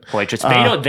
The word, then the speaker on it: Poitras: He's like Petri. Poitras, it's like a Petri dish. Yeah, some shit. – Poitras.